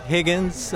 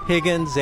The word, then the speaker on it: Higgins: My name is Leonard Higgins. Leonard is L-E-O-N-A-R-D, Higgins, H-I-G-G-I-N-S.